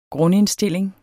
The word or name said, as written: GRUNDINDSTILLING